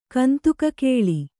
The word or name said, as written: ಕಂದುಕಕೇಳಿ